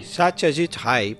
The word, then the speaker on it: Satyajit Ray: Parece que a pronúncia lá em Bengala, né, em Calcutá, realmente é Ray. Satyajit Ray.